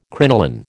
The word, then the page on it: crinoline: American English